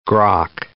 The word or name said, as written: grok